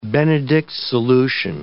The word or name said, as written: Benedict's solution